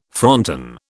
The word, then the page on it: fronton: British English